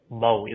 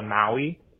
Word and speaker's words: Maui: Americans really flatten out that kind of A vowel sound in it. So, like, in Hawaiian, it is more of like Maui as opposed to Maui.